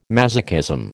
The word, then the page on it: masochism: American English